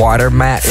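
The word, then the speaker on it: Watermät: Het is er eentje van Robin Schulz en Watermat, denk ik dat je het moet uitspreken. – Watermat, ja.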